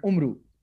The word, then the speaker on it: Umru: And Amir Hamza's best friend is this trickster whose name is, in Arabic, they say Amar Ayyar, but it's in Urdu, I didn't know that you pronounce it a certain way. So I just, everyone in Pakistan calls it Umru.